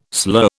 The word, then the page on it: slur: British English